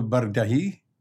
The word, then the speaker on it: Bardaji: And our special guest today is Rafael Bardaji.